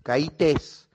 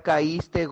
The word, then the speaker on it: caíste: No se dice, ya caíte, caítes. No se dice, se dice, ya caíste gordo.